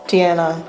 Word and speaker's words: Diena: Do I pronounce it Diena or Diena? – Diena.